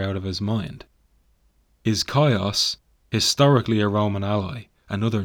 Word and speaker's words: Chios: I was pronouncing it Chios in previous episodes. I do apologise. My pronunciation of Greek places is pretty shabby sometimes. Chios is that little island city-state off the west coast of Anatolia.